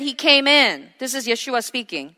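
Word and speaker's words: Yeshua (ישוע): So, in English, it says Jesus, but in his original pronunciation, it's Yeshua, Yehoshua, the same meaning, which means the Lord saves.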